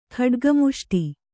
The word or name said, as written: ಖಡ್ಗಮುಷ್ಟಿ